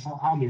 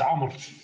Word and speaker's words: Amr: It's just A-M-R. Amir, what was the right pronunciation? – It's Amr. Yes, it's okay. – I'm sorry, I can barely hear you. – It's Amr. – What's the name? – Amr. It's a letter that doesn't exist in English.